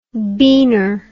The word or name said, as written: beaner